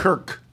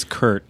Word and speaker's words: Kirk: How did you pronounce that? – Kirk, K-I-R-K. – I think it's Kurt. – The general manager and president pronounces it Kurt, is deliberately, and then says it's because of his accent, which is ludicrous.